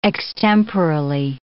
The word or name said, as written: extemporally